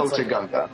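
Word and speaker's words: Otoh Gunga: It reminds me of a way cooler Otto Gunga, or however you pronounce that, the capital of the Gunga yes. – city. – But Ota Gunga.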